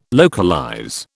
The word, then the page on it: localize: British English